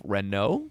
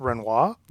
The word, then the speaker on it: Renault: This is a 1912 Renault, I believe is how you pronounce that. I know because I went to YouTube and typed, how do I pronounce Renault? – And it told me. – That's how James Cameron pronounces it.